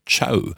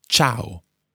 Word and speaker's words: ciao: Quite often people think it's ciao, almost as if it's spelled C-H-O-W, but it's not. It's ciao.